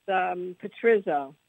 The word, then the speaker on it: Pizzero: Oh, it's Pizzero. – Pizzero, okay. Just like it looks, okay.